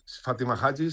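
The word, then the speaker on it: Fatima Hajji: Claro, Fátima Haji, no sé si lo he dicho bien. – Fátima Haji, sí.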